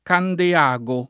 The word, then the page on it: Candeago: lingua italiana